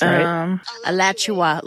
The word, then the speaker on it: Alachua: Alachua, is that how you say it? – Alachua, like Joshua, but Alachua.